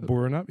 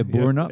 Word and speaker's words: Boranup: You know, down in, how do you pronounce it, Boranup? – Oh, I say I always grew up saying Boranup, but Boranup. Yeah, Boranup. – Yeah. Either.